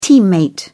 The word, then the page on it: teammate: British English